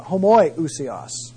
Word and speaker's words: homoiousios: And that's the term homoi Osios. I'll spell that for you because I guess I didn't write it up there. H-O-M-O-I Usios. O-U-S-I-O-S. Another term that was under consideration was this term, homoousia, homoiousios, H-O-M-O-I-ousios, O-U-S-I-O-S.